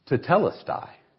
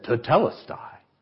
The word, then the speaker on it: tetelestai: It is a Greek word and is pronounced tetelestai. The man said, tetelestai. Tetelestai. The word is tetelestai.